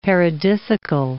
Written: paradisical

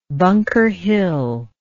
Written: Bunker Hill

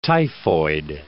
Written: typhoid

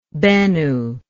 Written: Benu

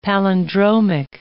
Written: palindromic

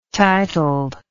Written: titled

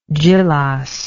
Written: djilas

